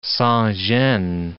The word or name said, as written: sans gene